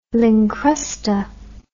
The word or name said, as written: lincrusta